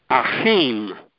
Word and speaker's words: Achim: But do, as you read the Roman letters, pronounce it in Hebrew. Achim. It means brotherhood in Hebrew.